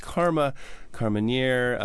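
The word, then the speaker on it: Carmenère: Carmenere, which would be the Chilean pronunciation.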